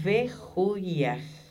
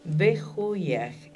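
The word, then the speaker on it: Behuiah: Tu ángel se llama Bejuyaj. B corta E H-U-I-A-H. Cada J que yo pronuncio es una H. – Behuyaj es un serafín, es un ángel de fuego, un llameante.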